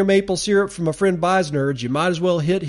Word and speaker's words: Beisnerds: That's at B-E-I-S-N-E-R-D-S, B-E-I-S-N-E-R-D-S. That's pronounced Beisnerds, like nerds, but with B-E-I-S in front of it.